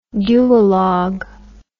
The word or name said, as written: dualogue